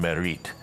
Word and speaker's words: Mareridt: called Merit, which is spelled M-A-R-E-R-I-D-T.